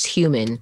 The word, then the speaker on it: human: Like you don't say the H at all. Like when your teacher is teaching it to you that way, you're just like, I guess that letter is silent. Yeah. But you're supposed to say like Hugh Grant. – It's human. – Human. – Yeah. – Got to make that H noise in there.